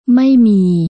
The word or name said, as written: ไม่มี